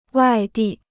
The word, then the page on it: 外地: wài dì